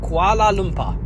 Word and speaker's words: Kuala Lumpur: Kuala Lumpur. – Kuala Lumpur, well pronounced.